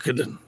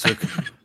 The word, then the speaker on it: Suikoden: Suikoden. – It's Suikoden.